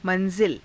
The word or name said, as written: ma^zila